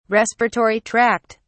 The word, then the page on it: respiratory tract: British English